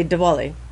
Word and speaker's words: Diwali: It's pronounced Diwali. – I say Diwali.